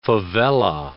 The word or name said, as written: favella